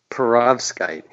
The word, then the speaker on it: perovskite: I never heard it, but I think you pronounce it perovskite. It sounds like a Polish potato blintz or something.